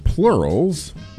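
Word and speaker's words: PLRLS: And before that, it's pronounced plurals, but it's P-L-R-L-S with the song Curses from their 2023 Curses album.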